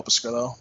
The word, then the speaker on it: Pescrilo: You can find me on Twitter at Paul Pescrillo. My last name is spelled P-E-S-C-R-I-L-O.